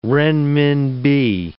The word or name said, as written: renminbi